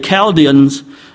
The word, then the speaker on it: Chaldeans: Verse 8, therefore at that time certain Chaldeans were, it's pronounced with a K, Chaldeans, with these crazy folks who lived north of Babylon.